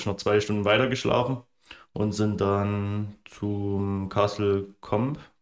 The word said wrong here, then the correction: Castle Combe: Castle Combe, ja. Castle Combe.